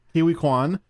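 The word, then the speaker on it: Ke Huy Quan: Kiwi Kwan.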